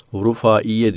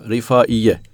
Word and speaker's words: Rıfaiye: Rıfaiye veya bazen Rıfaiye diye U telaffuzu şeyler vardır. Fakat doğrusu Rıfaiye imiş.